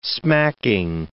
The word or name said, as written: smacking